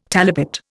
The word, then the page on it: talipot: Indian